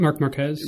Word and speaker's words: Marc Marquez: Marc Marquez.